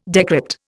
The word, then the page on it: decrypt: Indian